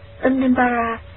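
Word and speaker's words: Udumbara: Xin mở ngoặc chú thích chữ Ưu Đàm Bát La, nguyên âm Brahma, Ấn Độ đọc là Unnimbara,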